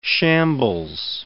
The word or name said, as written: shambles